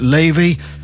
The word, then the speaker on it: Laufey: You spell it L-A-U-F-E-Y, but you pronounce it Levy.